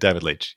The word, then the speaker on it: Leitch: I pronounced both of the names terribly. – Chad Stahelski and David Leitch.